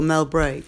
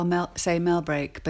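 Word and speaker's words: Mellbreak: Do you pronounce it Mel Brecq or Mel Brake? – I would probably normally say Mel Brecq, but if you were an old Cumbrian from a different generation, you'd definitely say Mel Brecq.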